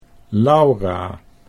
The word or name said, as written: Laura